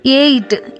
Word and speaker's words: EIGHT: TIGHT ಟೈಟ್, EIGHT ಏಯ್ಟ್, ಇಲ್ಲಿ G ಅನ್ನೋ ಶಬ್ದ ಉಚ್ಚಾರವೇ ಆಗಲಿಲ್ಲ.